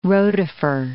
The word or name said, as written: rotifer